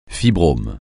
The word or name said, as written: fibrome